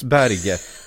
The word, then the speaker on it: Berge: Jo, det är, han... norskt och det uttalas Berge. Det är bara att, att han av någon anledning försöker få någon slags internationell touch på det.